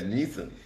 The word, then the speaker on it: Nissan: That Nissan. – That's how it's pronounced.